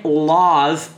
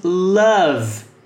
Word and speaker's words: love: Lembrando que a pronúncia não é love, e sim love.